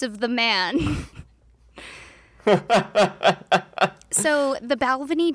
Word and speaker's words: Balvenie: Luckily, Esquire totally came through for me by having two videos with the same man pronouncing it two different ways. The Balvenie, or the Balvenie. I'm going to go with the Balvenie because in that video, the man was holding a glass of scotch, and that just feels more right to me.